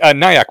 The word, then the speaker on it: NAIAC: The National AI Advisory Committee, NAIAC, do you know how to pronounce that shortly? Is it like NIAC? – Yeah. – Okay, NIAC. What do you guys think of NIAC?